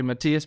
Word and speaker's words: Matias: Yes, you said it correctly, Matias Bichara.